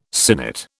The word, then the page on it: sinnet: British English